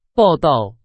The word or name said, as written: 报到